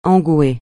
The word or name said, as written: engouer